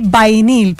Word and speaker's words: Vinyl: Yo hace poquito, miren, les voy a contar, hace poquito encontré un labial que se llama el Power Stay Creamy Vinyl, porque incluso les había dicho vinil, no, es vinyl, ya aprendí una mejor pronunciación, y es de Avon y ustedes no saben la belleza, porque imagínense que dura hasta 16 horas y se ve como brillosito.